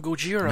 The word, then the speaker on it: Gojira: I've heard the other people on Odo Island call him Gojira. – Nah, it's Godzilla.